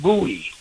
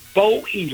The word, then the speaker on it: Bowie: Nothing drives me crazier than to hear someone in current times playing a song from yesterday, and they say, that's David Bowie. And I'm going, wait a minute. and I think for me, oh, they mean David Bowie, long O.